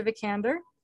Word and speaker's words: Vikander: It stars Domhnall Gleeson, Oscar Isaac, and Alicia Vikander.